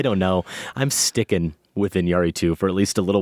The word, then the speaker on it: Iñárritu: Not Iñárritu, but Iñárritu. The accent is on the A.